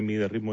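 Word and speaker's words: Mydia Ritmo: Se escribe Media Ritmo, MYDIA. Ritmo. Uh-huh.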